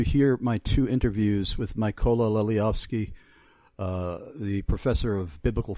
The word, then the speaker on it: Mykola: Mykola, M as in Michael, Y-K-O-L-A, Mykola, which is the Ukrainian pronunciation for Nikolai, pronunciation and spelling. But M-Y-K-O-L-A, that's M as in Michael, Y-K-O-L-A, and you will see both of those audio links.